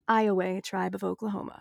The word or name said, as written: Iowa